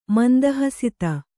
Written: ಮಂದಹಸಿತ

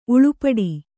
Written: ಉಳುಪಡಿ

